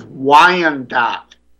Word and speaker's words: Wyandotte: The proper pronunciation of that is Wyandotte, the old Wyandotte Indian tribe.